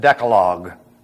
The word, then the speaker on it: Decalogue: And this is called the commandments, or the Decalogi. Only we pronounce it Decalogue.